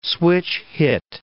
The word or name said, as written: switch-hit